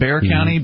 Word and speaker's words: Bexar: I think I was told it's pronounced Bear County, B-E-X-A-R. Is that right? Bexar County? Yes, okay.